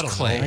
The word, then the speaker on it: Klee: But it always looks like Klee. – Who cares? – We don't. I don't know. – Correct. Pronounced Klee.